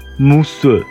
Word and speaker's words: mousse: 今天教大家法语，气泡的发音。 Mousse。